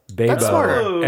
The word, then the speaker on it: Baebo: Yeah, and so then she, I think she's changed it B A E B O, like Bay. – Ah. Beba.